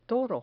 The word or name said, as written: toro